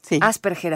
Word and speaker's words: Asperger: Asperger, así es como se, pronuncia.